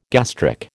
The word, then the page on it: gastric: American English